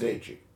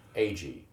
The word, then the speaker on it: Agee: James Agee. – Agee, okay. – Agee.